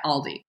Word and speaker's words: Aldi: I've been pronouncing it wrong. It's A-L-L-D-I, Aldi. – That's how it's pronounced. It's spelled A-L-D-I.